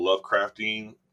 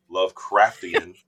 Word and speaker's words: Lovecraftian: And in this week's lesson, we're talking about the Lovecraftian. Oh my God, I said that wrong. Lovecraftian descent into weirdness.